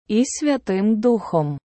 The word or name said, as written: і Святим Духом